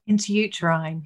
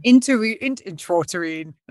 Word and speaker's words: intrauterine: So as a starting point, explain what IUI, or intrauterine insemination. Did I say it right? – Intrauterine. – Intrauterine.